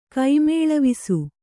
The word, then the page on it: ಕೈಮೇಳವಿಸು: kaimēḷavisu